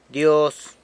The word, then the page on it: Dyoz: sanlucasquiavini